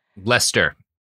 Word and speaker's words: Leicester: I know that because I've pronounced it wrong on the show, and everyone in England is like, how did you not guess that it's pronounced completely differently from how it's spelled? – But it's Leicestershire.